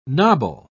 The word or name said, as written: nobble